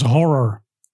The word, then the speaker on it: HO9909: Daar staat er HO9909. Maar dan moet je dat uitspreken als horror.